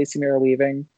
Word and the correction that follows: Samara Weaving: Samara Weaving.